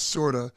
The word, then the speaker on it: Corda: It's spelled C-O-R-D-A, but pronounced with like an S, Sorda.